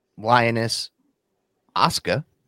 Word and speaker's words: Lioness Asuka: Yeah, one's Lioness Asuka.